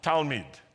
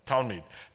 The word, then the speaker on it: Talmid: Okay, now how do you say one disciple in Hebrew? Talmid. Talmid. Talmid in the singular, Talmudim in the plural.